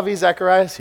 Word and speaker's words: Zacharias: I was listening to an interview, Ravi Zacharias. Yes, that's how you say it.